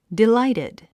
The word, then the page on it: delighted: diláitid; ディライテッド